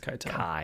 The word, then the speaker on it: Keitel: How do you say his last name? Keitel? – Harvey Keitel. – Keitel? – Yeah. – Okay, I've always said Keitel for some reason. – Yeah, it's Keitel. For sure.